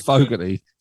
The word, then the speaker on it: Fogerty: And I think they all said it's Fogerty, but is it like, well, they don't pronounce the T either, probably. They pronounce it as a D, probably, don't they?